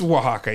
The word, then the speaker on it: Oaxaca: Oaxaca State, southwest Mexico. – Like, isn't that pronounced Oaxaca? – I was going to say it's pronounced weird. – It's Oaxaca, yeah. Sorry.